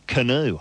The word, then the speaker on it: Canut: We had a bloke living in our town whose surname was spelt C-A-N-U-T, which is pronounced canoe.